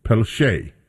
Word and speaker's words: Pelletier: It looks like Pelletier, and that is the way that many people would pronounce it. However, I happen to know that there's a French pronunciation that is also Pelletier. P-E-L-L-E-T-I-E-R.